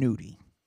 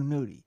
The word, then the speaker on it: Nudy: N-U-D-Y. That's how you pronounce it. – Oh, well. And this was, and they were like, oh, Young Nudie.